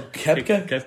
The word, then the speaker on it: Koepka: Yeah, this is what you call Koepka? – Koepka. – That's his name.